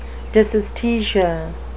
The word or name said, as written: dysesthesia